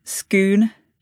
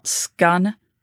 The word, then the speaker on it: Scone: It's pronounced scoon, not scun.